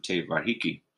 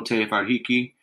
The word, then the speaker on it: Te Whariki: And the last couple of years, we've been inspired by the New Zealand Ministry of Education's curriculum Te Whariki. If you don't know Te Whariki, go check it out.